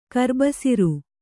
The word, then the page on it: ಕರ್ಬಸಿರು: Kannada